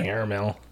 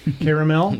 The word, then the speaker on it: caramel: Caramel. That's a good stripper name. – Caramel? – Yeah. – Oh. – No, caramel, not caramel. – Oh, yeah, that's better. – Two syllables, not three.